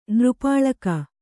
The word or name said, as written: ನೃಪಾಳಕ